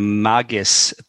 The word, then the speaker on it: Magis: This was a production of the Magis Theater Company. I believe that's how it's pronounced, M-A-G-I-S.